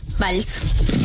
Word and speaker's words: Valls: ¿Valls? – Valls.